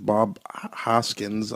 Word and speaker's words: Bob Hoskins: Two main people that stood out, which is Bob Hoskins.